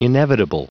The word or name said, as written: inevitable